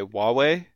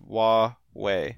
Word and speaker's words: Huawei: Oh, is that how you say that? I'm always like, it sounds like Hawaii to me. – It looks like that, Yeah. – yeah, but I think it's pronounced Huawei.